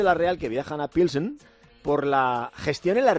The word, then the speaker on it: Pilsen: En Pilsen, ¿no? Se pronuncia Pilsen. Bueno, a Pilsen, Pilsen.